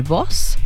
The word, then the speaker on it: buzz: Mira, vamos a ver este corte que no puede faltar, que es el boss, que se escribe B-U-Z-Z, el boss, que es como muy rapadito.